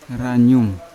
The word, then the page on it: ranyum: Yawa